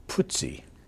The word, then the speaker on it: Putzi: Uh, Putzi. – That's the way you pronounce it.